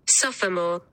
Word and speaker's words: sophomore: But the British pronunciation, in fairness to Matt, is listed as having three syllables. – Sophomore.